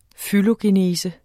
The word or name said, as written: fylogenese